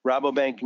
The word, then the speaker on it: Rabobank: And I guess, do you say Rabobank? Am I saying it correctly? – Rabobank. – Okay.